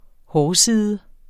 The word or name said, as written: hårside